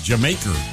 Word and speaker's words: D'yer Mak'er: But in the part of England where guys from Led Zeppelin were from, the way they would pronounce Jamaica was a little different. They would say Jamaica. – Oh, And so that's what they named this song. It's spelled funky. It's, you know, like D-Y-E-R-M-A-K-E-R, but it's technically the pronunciation of Jamaica with the British scouts attached, right? Jamaica.